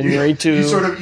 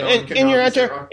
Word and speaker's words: Iñárritu: That's it. Iñárritu. Iñárritu. – You sort of rush through the first two syllables. – Yeah. Iñárritu.